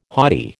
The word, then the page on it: haughty: American English